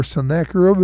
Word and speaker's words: Sennacherib: There's under the Assyrian control of Sennacherib, or Sennacherib, if you may want to pronounce it that way.